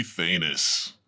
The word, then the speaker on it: Thanos: I escaped the quantum realm after being snapped away by the almighty Thanos. – Ah, you pronounce it right.